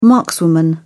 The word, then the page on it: markswoman: British English